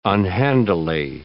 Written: unhandily

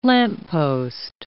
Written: lamppost

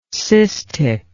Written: cystic

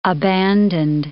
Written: abandoned

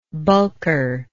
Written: bulker

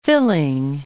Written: filling

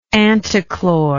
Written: antichlor

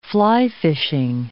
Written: fly-fishing

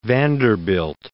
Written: Vanderbilt

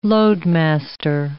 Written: loadmaster

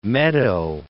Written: meadow

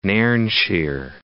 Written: Nairnshire

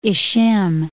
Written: Ishim

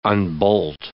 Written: unbolt

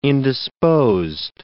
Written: indisposed

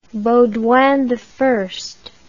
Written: baudouini

